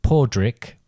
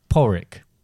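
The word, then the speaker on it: Pádraig: Well, you might think that working on the basis that it changes it to something similar, but it's pronounced Pordrick or Porrick.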